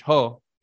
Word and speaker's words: JOH: There was a so-called president named Juan Orlando Hernandez, who's known by his initials J-O-H, which you pronounce in Spanish, Ho.